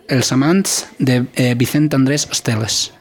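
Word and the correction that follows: Estellés: ¿Esteles es? – Vicent Andrés Estelles. – Estelles.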